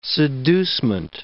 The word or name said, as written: seducement